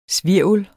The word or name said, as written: svirvel